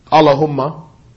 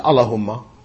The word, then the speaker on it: Allahumma: Different from the mispronunciation commonly found among non-Arabs, Allahumma. It's not Allahumma, but Allahumma. Just as Allah's name is not Allah, non-Muslims say this, Allah. It is Allah. So, Allahumma.